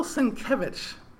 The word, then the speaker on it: Sienkiewicz: Well, I liked Bill Sienkiewicz, and I think that's pretty sure how you pronounce it.